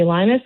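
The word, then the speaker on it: Llinas: My name is spelled AmyLlinas. That's Amy Linus.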